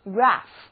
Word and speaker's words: graph: 字母 a 在这里要发长的啊，而字母组合 ph 发音是，所以念作 graph， graph，坐标图、气温图表都是 graph。